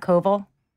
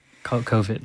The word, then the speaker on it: COVID: It's not Koval. – And that kind of rhymes with Arvid. Okay. Co- like COVID, like spelling.